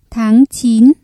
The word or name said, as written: tháng chín